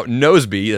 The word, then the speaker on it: Nozbe: Now, Nosebe, that's spelled N-O-Z-B-E,